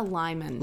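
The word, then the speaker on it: limón: What is a limon? – Lime, it's just lime. Lime flavor. – Oh. – Limon is, you know, how it's pronounced in Spanish.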